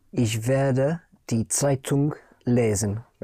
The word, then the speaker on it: Zeitung: Spelled Z or Z, E-I-T-U-N-G. Die Zeitung. And the N-G in German, unlike in English, the N-G is pronounced. Zeitung. The G comes out, die Zeitung.